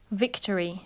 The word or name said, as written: victory